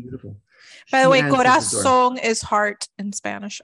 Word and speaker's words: Corazon: Corazon. – Corazon. How do you say it? Carazon? – Corazon. Amurao. I just saw her picture, actually. Hold on, let me find it. Corazon Amurao. – Amurao. Thank you.